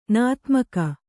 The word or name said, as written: ನದೀಮುಖ